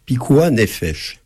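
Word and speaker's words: pikuah nefesh (פיקוח נפש): Alors, il y a un principe dans le judaïsme, pikuah nefesh, sauvegarde de la vie. – Pikuah nefesh. – Alors, je prononce évidemment très mal, mais vous prononcez beaucoup mieux. – Non, mais c'est très bien.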